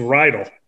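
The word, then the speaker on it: Reidel: It's Rydell.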